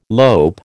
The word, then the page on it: lope: American English